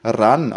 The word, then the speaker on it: Ran: Der wird ja wirklich Ran ausgesprochen, laut Internet. Siehst du das auch so, ja, dass der R-A-N gibt es bei Prime zu streamen?